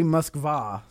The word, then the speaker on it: Moskva: It's actually Moskva.